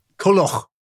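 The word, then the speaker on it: Culhwch: I can see Kulhuch and Olwen. Is that right? – Yeah. – Kulhuch. – Yeah. – Kulhuch.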